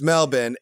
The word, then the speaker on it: Melbourne: You can't pronounce the R. They get furious. So it's Melbourne.